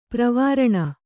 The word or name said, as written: ಪ್ರವಾರಣ